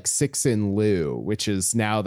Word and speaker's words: Liu: And then, you know, for comparison, Ken Liu, that last name, Liu, it's usually, mm-hmm. you could say it like that, Liu.